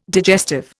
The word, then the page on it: digestive: Indian